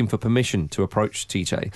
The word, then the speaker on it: Tite: Tite. – Or Chiche, would it be pronounced? – Yeah.